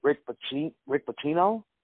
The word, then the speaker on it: Pitino: Is it Rick Pitino? – Yeah.